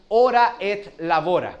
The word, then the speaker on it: ora et labora: Se llama, la expresión se pronuncia ora et labora.